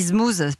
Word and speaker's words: Zmooze: Alors on dit Zmooze parce que ça s'écrit avec un Z.